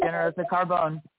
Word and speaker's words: Ginearosa Carbone: How you doing, Gilles? Am I pronouncing that right? – Yeah, it's Ginearosa Carbone.